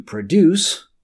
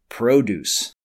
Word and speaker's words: produce: There's a slight difference in the way we pronounce the verb and the noun. To produce, or fresh produce.